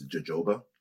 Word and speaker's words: jojoba: So jojoba oil, it usually gets mispronounced as jojoba.